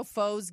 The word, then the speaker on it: faux: It was zero foes given. But you know, it's spelled F-A-U-X, yes.